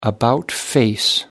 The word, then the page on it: about face: British English